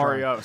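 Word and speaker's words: Mauriat: For example, you mentioned Love is Blue by Paul Mario and his orchestra. – Oh, Mario, sorry. – Yes, yes, the T is silent. He is French.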